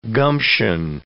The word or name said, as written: gumption